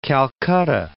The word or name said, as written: Calcutta